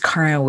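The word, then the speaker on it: Karin: I am your host, Karen Weary.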